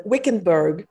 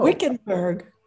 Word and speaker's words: Wickenburg: Wickenburg. Oh, Wickenburg.